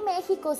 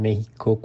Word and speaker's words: México: ¿Alguna vez te has preguntado por qué México se escribe con X pero se pronuncia como si fuera una J?